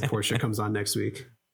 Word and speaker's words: Porsche: Porsche.